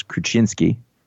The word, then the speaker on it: Kruczynski: I know how to pronounce CZY because of our writer Jimsky. – Right. – So I know that the CZ is a ch, so it's Krasinski.